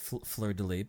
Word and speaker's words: fleur-de-lis: The fleur-de-lis. I don't know. – Fleur-de-lis, but that's fine.